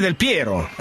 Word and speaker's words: Del Piero: Ah, per i futuri del Piero, Ho detto bene. Hai detto bene.